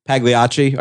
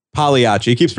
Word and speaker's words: Pagliacci: I also like he turns on Pagliacci. I keep pronouncing it Pagliacci. – Wrong. Pagliacci.